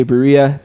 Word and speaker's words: Barea: He really gave some good minutes, and J.J. Barea, is that right? Did I say that right? – Yeah.